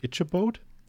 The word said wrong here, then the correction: Ichabod: Ichabod. Ichabod. – Ich was? No? Hm? Ichabod. Ichabod, ja, das klingt professionell. Ichabod.